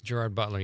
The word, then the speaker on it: Gerard Butler: It's I think Gerard Butler. That's how you say it, right? – Gerard Butler.